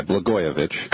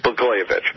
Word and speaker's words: Blagojevich: The J is a Y, Blagojevich. – Blagojevich.